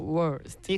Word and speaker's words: worst: worst. – 이 끝 부분이 중요하죠. th, z, and st.